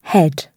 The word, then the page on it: head: British English